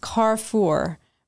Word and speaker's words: Carrefour: Is pronounced Carrefour. By the way, it means crossroads in French.